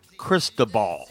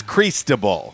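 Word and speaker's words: Cristóbal: Uh, by the way, that's not the uh, that's not the appropriate way to call it. That's not how you pronounce it. How do you? It's Cristobal. Cristobal. Cristobal. Okay, but uh, it could uh, impact the NEA middle of next week.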